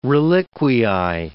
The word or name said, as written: reliquiae